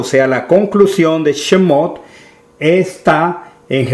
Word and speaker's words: Shemot: La semana pasada estuvimos iniciando el estudio de Shemot. Es así como se pronuncia en hebreo la palabra éxodo,